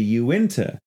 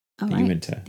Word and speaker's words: Uinta: And I'm very grateful for it, that in the episode, the Skinwalker Ranch episode, I refer to the area of Utah as the Uinta Basin. It is actually pronounced the Uinta Basin. – Uinta. The Uinta. – Right. Not the Uinta. In reading it, I was weirdly I was, the N and the I were switching places.